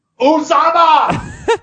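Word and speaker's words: Uzomah: Uzoma. – Set it straight with the pronunciation. – Uzama!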